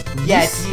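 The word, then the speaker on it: Ys: I don't even know if I'm pronouncing it right. Y-S. – Yes.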